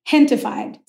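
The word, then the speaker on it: Gentefied: Hentified. So it's a play on gentrified, but gente is the word in Spanish for people.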